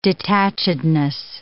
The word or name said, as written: detachedness